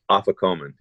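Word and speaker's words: afikoman: So the actual things that Jesus did, like when we celebrate communion, that was the how do you pronounce it? Ophicomen? – Yeah, Ophicomen. Yeah.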